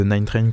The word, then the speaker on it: 9Trane: Euh, il fait partie de ces nombreux édits euh, de Nine Train que vous pouvez aller retrouver sur euh, son bandcamp. Ça s'écrit 9 T-R-A-N-E.